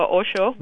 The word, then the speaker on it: Osho: But something that has helped me very, very much is something that Osho, the philosopher Osho, right. – S-H-O, I don't know if I'm pronouncing his name correctly. – Yes, you got it right.